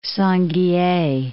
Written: Sangihe Islands

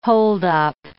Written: holdup

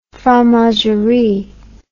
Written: fromagerie

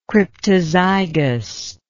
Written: cryptozygy

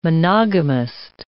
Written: monogamist

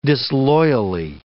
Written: disloyally

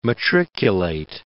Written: matriculate

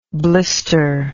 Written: blistered

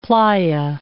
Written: playa

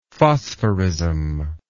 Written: phosphorism

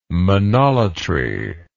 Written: monolatry

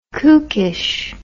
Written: kookish

